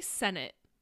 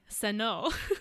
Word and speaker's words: Sennott: The biggest news that I will start us off with that I am most excited about is that Rachel Sennott, I never know how to pronounce her last name, actually, now that I'm saying it out loud. – I say Sennott.